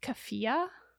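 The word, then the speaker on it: keffiyeh: I believe it's pronounced kafia.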